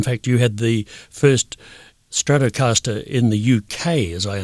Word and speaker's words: Stratocaster: And you thought, that guitar that he's playing, that Stratocaster, is that how it's pronounced? – Stratocaster.